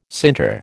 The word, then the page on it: sinter: American English